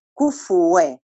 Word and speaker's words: كفوا: ناس بتقرأها بتسكين الفاء لكن هي الفاء مع الكاف مضمومين، كفو كفوا أحد مش كفوا أحد، خلي بالك عشان لو بتقرأيها في الصلاة.